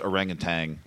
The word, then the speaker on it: orangutan: The the film drunk broadcast style guide demands that it's pronounced orangutan.